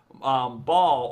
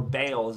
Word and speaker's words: Baal: And I don't mean to get too like fucking up my own ass in education or religious knowledge, but ball or Baal, as it's sometimes pronounced, is was a false god from the Bible.